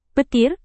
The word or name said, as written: petir